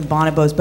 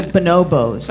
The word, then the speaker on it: bonobos: I'm going to now pronounce them bonobos, but correctly. – I usually pronounce them bonobos.